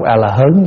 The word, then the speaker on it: A-la-hớn: A-ra-hát chúng ta đọc là A-la-hán. Các ngài dạy thôi đọc A-la-hớn đi. Cho nên thỉnh thoảng nghe quý hòa thượng giảng A-la-hớn, chắc hòa thượng này nói nhầm, không phải đâu.